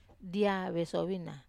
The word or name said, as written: dia vesovina